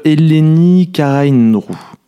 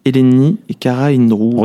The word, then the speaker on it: Eleni Karaindrou: Tout simplement, ça se prononce Eleni Karaindrou. – Redi ? – Eleni Karaindrou.